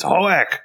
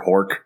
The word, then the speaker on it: Hoek: I mean, Ren's last name, Hoek, nobody ever pronounces it correctly. I forget what the episode it was. It wasn't one of the ones we watched, but there's definitely episodes where Ren corrects people. It's Hoek! But, like, they kept calling him, like, Commander Hork. Hork came through a lot, and a lot of Hoke and things like that.